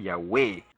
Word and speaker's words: Yahweh: Entonces, que no vengan estas personas, ¿verdad?, a decir otra cosa cuando los mismos rabinos, los sabios de Israel, reconocen que ese es el nombre verdadero, la pronunciación correcta del nombre del Padre, Yahweh.